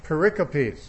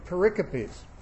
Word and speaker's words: pericope: It's spelled like it'd be pericope. P-E-R-I-C-O-P-E. It sounds like it should be pericope, but it's actually pericope, and it's a Greek word.